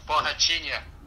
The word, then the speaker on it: Borrachinha: Paolo Borahina, is coming in. Did I say that right? – Borahina. Nah, not quite. – Borrachinha.